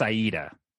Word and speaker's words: Aida: And there was talk about, um, hope I'm saying this correctly, Quo Vadis Ada. Am I saying that correctly? – Quo Vadis Aida. – Aida, thank you very much.